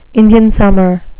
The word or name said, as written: indian summer